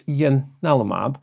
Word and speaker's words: ianalumab: But I checked, and apparently the right way to say it's ianalumab, because it was named according to the Roman god Janus, who is two-faced god.